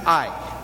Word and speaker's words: Ai: Actually, the Hebrew word would just be pronounced Ai.